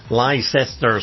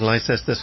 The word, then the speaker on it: Leicester: And the thing is, they're quite right. The pronunciation is right. If you pronounce it as it's written, uh-huh. – it's Leicester Square. But we're just slurring. Leicester. Because we're drunk most of the time.